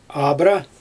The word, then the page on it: abra: Xyzyl